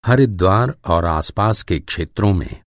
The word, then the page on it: हरिद्वार: ہردوار